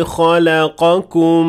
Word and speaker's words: خَلَقَكُمْ: The first one is indeed the correct recitation. – خَلَقَكُمْ. – The second one is the wrong one. – خَلَقَكُمْ.